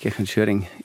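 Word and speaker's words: Kirchanschöring: Habe ich es richtig ausgesprochen? Grüß Gott. – Guten Morgen, ja, grüß Gott. Ja, natürlich, Kirch an Schöring ist richtig ausgesprochen.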